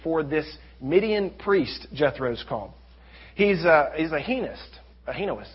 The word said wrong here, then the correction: henotheist: He's a henotheist.